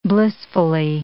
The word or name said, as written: blissfully